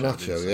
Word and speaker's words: Garnacho: Is he Garnacho? Have I said it right this time? I got it completely wrong in the live show. – Garnacho, yeah.